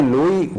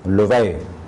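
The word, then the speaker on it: لؤی: لوے کو بھی عام طور پر لوگ تلفظ کرتے ہیں لوئی، وہ تلفظ غلط ہے، صحیح تلفظ ہے لوے۔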